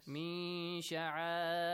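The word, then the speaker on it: Min sha'airillah (مِن شَعَآئِرِ ٱللَّهِ): Let your listener understand that the letter sheen comes after noon sakin. Min sha'a. Do not say min. Clearly do not pronounce noon. Min sha'a. Actually, ikhfa is opposite to idhar. Idhar, you make it clear. You pronounce noon clearly. But ikhfa, no. Hide it a bit.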